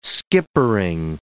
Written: skippering

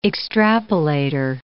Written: extrapolator

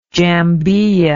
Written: jambiya

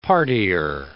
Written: partyer

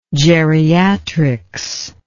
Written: geriatrics

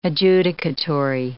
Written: adjudicatory

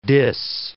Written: diss

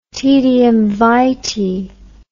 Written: taedium vitae

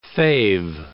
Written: fave

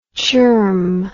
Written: chirm

